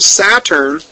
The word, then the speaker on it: Satur: It says, now, the name Saturn in Chaldee is pronounced Satur, S-A-T-U-R.